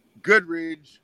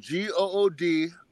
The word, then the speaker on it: Goodridge: So, my Twitter and my Instagram is CT Goodridge, which is spelled GOODRIDGE _185.